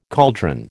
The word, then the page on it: cauldron: American English